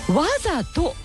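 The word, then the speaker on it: 態と: さあ、そしてディレクターが調べてくれました、岩窟王さんからいただいた 4 句目、ゴールデンウィーク、これ状態の体、わざと、状態の体にと、ひらがなのとをつけるとわざとと読むそうです。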